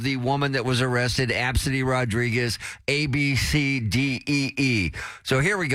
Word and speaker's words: Abcde: Hey, I've seen that name Absidy many a times. I work in HR, and we have quite a few employees with that name. At first, I didn't know how to pronounce it because I saw ABCDE.